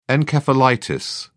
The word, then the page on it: encephalitis: British English